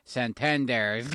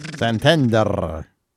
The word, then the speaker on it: Santander: Like whenever I say, like, oh, we're going to go see a show at the Santander Center. You know, you got to trill your R's. Santander. – Santander.